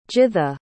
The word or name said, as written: githa